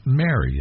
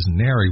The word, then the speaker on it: Nary: It's not Mary. And I want to make sure I'm saying this correctly so people can hear me. But it's not a woman's name. The song is Nary, which in my mind, I think I know what the word means, but I want you to explain what it's about.